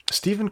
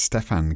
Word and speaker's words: Stephen: Stephen Curry, underrated. – Actually, it's Stefan Curry, I've learnt. But it's written Stephen, but he pronounced it Stefan.